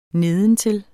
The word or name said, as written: nedentil